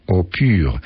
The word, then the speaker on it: Opur: Francuscy naukowcy z firmy o nazwie Opur, tu premia dla znających język Moliera, bo wymawia się to tak samo jak eau pure, czyli czysta woda, pracują nad opracowaniem metody pozwalającej na otrzymywanie wody pitnej z kropelek rosy zbieranych przy pomocy specjalnego kondensatora.